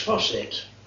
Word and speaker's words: Fawcett: His opponent was a Miss Fawcett. That's the correct pronunciation.